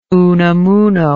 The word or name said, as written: unamuno